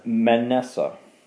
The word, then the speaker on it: Manasseh: So he took his two sons, Manasseh." Am I pronouncing it right? – Mm-hmm. – Manasseh.